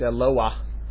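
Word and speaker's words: Eloah (אלוה): And while I'm at it, let me just point out the correct pronunciation of the word in that second parak is Eloah.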